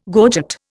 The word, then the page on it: gorget: Indian